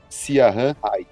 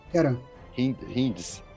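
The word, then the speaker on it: Ciaran Hinds: É C-I-A-R-A-N, só que como ele é irlandês, provavelmente, e eu acredito que é no gaélico irlandês, o C tem som de K, então é Kieran. – Kieran Hinds.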